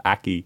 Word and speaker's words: Aki: It's spelled A-K-I, so my tendency is to pronounce it Aki, but it's Aki.